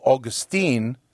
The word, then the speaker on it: Augustine: So when you drop the Latinized us, Augustinus, drop that, and then you have Augustine, but you want to put the emphasis on the second to the last syllable again, so they say Augustine.